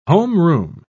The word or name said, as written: homeroom